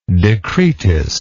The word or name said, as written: decretist